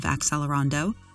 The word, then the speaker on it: accelerando: The word of the day is accelerando, spelled A-C-C-E-L-E-R-A-N-D-O. Accelerando is an adjective that means gradually increasing in speed. If you want to approximate the original Italian pronunciation of accelerando, pronounce the double C like ch instead, as accelerando. NPR's Patrick Jaranwatananon uses accelerando in his review First Listen, Vijay Iyer Trio, Accelerando, published on March 4, 2012.